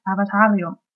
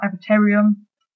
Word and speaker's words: Avatarium: And I listened to a very good new album by Avatarium, or Avatarium, if you spell it, yeah, in the English way.